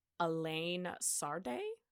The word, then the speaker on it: Alain Sarde: Uh, so French film actor and producer, I think you pronounce his name Alain Sarde, I think.